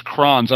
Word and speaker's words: Crohn's: I think it's Crohn's. I don't know if it's Crohn's. – Crohn's, yes. – Crohn's, okay. C-O-R-H-N-A. Yeah, Crohn's disease.